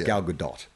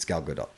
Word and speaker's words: Gal Gadot: It's Gal Gadot. We were saying it's Gal Gadot. – Gal Gadot. – Is that right? Yes, it's Gal Gadot.